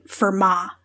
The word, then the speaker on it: Fermat: math class I've ever been in, it's been Fermat. Don't pronounce the T at the end.